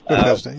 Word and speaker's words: Bethesda: And actually, I was reading through John yesterday, I believe it was, when they were at the pool of Bethesda, or Bethsaida, I forget how to pronounce it. – Bethesda, yeah. Mm-hmm.